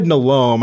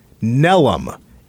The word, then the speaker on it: Nelome: Tyler, he said Nalome, and I used to say Nalome. I went to the pronunciation guide. I was a bit surprised. It's Tyler Nelum. Nelum is spelled N-E-L-O-M-E. Nalome, Nalome. I would have guessed those two before Nelum.